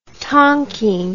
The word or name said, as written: tonking